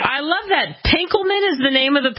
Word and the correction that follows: Benkelman: It's pronounced Benkelman. – Benkelman. – Spelled with an E instead of an A, so you say Benkelman. – Benkelman. I love it. – County Benkelman.